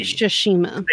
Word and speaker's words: Tsushima: I just like to say Tsushima. I don't think you pronounce the T. – Tsushima. – It's silent. It's a silent T. Tsushima.